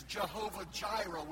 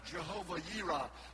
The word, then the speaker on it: Jehovah Jireh: For he said at that occasion that he is Jehovah Jireh, we say. Sometimes, brother, pronounced Jehovah Yireh.